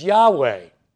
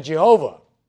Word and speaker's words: Yahweh: Most likely, the correct pronunciation of this Hebrew name for Lord is Yahweh rather than Jehovah.